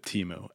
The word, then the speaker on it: Temu: Is it Temu or Temu? And they were like, it has many pronunciations, but most people go with Temu.